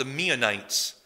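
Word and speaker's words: Meunites: There's a group that spells their name almost exactly the same way as the Ammonites, but it's usually pronounced the Meonites.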